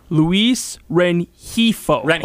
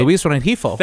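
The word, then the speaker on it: Luis Rengifo: So the pronunciation is Luis Renifo. – Okay. – Luis Renifo.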